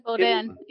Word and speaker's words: Boudin: Boudin balls, Boudain. – Boudin. Yeah, that just means sausage.